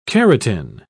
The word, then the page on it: carotin: British English